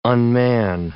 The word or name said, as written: unman